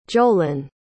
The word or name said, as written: jolan